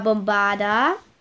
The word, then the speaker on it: Bombarda: Die Aussprache ist, also ich buchstabiere euch. B O M B A R D A. Eigentlich so, wie, man es spricht.